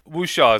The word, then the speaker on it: Wuxia: But I've heard it called Wuxia. – Wuxia is a pretty I'm also not terribly proficient, but Wuxia is a pretty common pronunciation for the word.